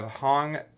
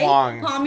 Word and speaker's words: Hoang: Today on our show, we have Hoang Tran. Did I say that right? – It's Hoang, but that's okay. – Hoang.